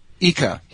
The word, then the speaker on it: Ica: I see a picture of an elongated skull from Paracas, and it's now at the museum in Ica. Is that how you pronounce it? – Ica.